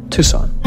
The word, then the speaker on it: Tucson: Nous sommes dans cette nouvelle émission pour la seconde fois à Tucson, Arizona, avec quelques petits problèmes de prononciation. Quelle est la bonne ? – Tucson.